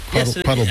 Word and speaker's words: Quattlebaum: It's kind of as I was brought up, it was Quattlebaum, but I've been here for a while. – Well, Baum. – But it's spelled Baum, as in the way Germans would pronounce Baum.